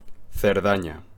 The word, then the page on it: Cerdaña: Spanish